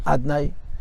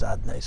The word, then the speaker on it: אדני: And the Gemara says, Leike elam azeh elam haba, in this life, Hashem's name is written Hashem, and it's pronounced Adnay. And la'asad lavik fish aninichtav aninikra, it's written Hashem, and it's pronounced Adnay.